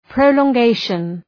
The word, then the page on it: prolongation: ,prəʋlɔ:ŋ’geıʃən